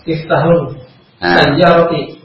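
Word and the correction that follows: miftahus sayyarati (مفتاح السيارة): Aina miftahus sayyarati.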